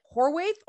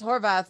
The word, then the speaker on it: Horvath: Well, because she screws people up by putting, if there was a V there, nobody would have any trouble saying Horvath. But you got the W. Why is there a W where there should be a V?